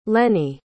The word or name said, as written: leni